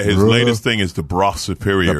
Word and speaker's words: Brough: Yeah, I think it's pronounced Bra Superior, I think. But B-R-A-U. – B-R-O-U-G-H. – Yeah, which is... – In England, it's a Bra.